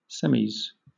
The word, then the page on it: semis: British English